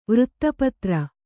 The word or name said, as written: ವೃತ್ತಪತ್ರ